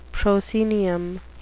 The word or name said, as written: proscenium